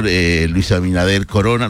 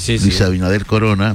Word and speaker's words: Luis Abinader Corona: Hasta el presidente, el señor Luis Abinader Corona, ¿lo he dicho bien? – Sí. – Luis Abinader Corona.